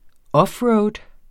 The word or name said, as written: offroad